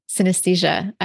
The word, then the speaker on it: synesthesia: But anyway, I'd love to hear your experience of synesthesia.